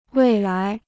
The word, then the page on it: 未来: wèi lái